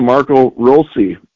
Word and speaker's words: Marco Rossi: And they talked about Marco Rossi in there, and anyone that watched the Iowa Wild games over the week found out that the preferred pronunciation for Marco Rossi is indeed Marco Rossi.